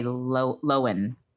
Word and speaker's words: Lohan: I think we're supposed to say Lohan. I don't think we're supposed to pronounce the H.